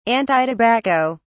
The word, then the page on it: antitobacco: British English